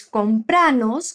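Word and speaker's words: cómpranos: Assim ela diz compranos com ênfase na sílaba pra.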